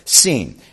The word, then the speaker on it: Sin: The way it's spelled, you would think it should be in John Bunyan's Pilgrim's Progress, the wilderness of sin. But I think it's pronounced seen.